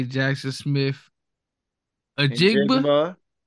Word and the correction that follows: Njigba: Njigba. – Did I say that right? – Njigba.